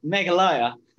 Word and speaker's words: Meghalaya: Correct, Meghalaya. Just like Himalaya, Meghalaya.